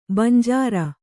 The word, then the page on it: ಬಂಜರು: banjaru